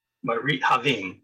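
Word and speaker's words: Marit Haavimb: Capital K-I-I-C, K-I-D-Z. And it's Kimberly Pierce, and I looked up the other name. You can check me on the pronunciation. Marit Havim? – Marit Havim, yes. – Yes, H-A-A-V-I-M-B.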